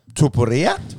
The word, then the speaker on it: Topuria: And we finish with the featherweight battle with Mitchell taking on how do you say, Tupuria. absolute speculation. Mm-hmm. I've heard of Bryce Mitchell. I've never heard of Ilya Topuria.